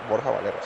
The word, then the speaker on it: Borja Valero: Borja Valero, sí, correcto por Jabalero, el amor platónico de Pau.